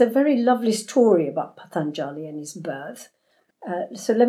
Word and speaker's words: Patanjali: So Patanjali, am I saying that right? – You are.